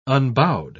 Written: unbowed

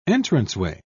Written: entranceway

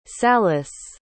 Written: salus

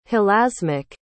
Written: hilasmic

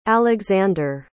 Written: alexandre

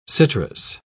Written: citrus

